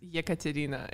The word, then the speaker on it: Yekaterina: Just Yes, it's Yekaterina. It's it's difficult to pronounce, especially for American people.